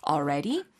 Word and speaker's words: already: 차라리 이때는 L를 포기해 버리시고요. L을 발음하지 말고 어까지만 발음하고 ready라고 바로 이어지는 건 어떨까요? Already? – That's right.